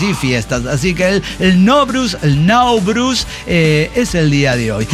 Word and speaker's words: Nowruz: Así que bueno, y hoy es el Nowruz, o el Nowruz, como se pronuncia, que es el año nuevo persa.